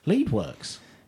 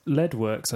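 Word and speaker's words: Leadwerks: Yes, tell us all about Leadworks. – Yes, Leadworks, I think you'll find it's actually pronounced.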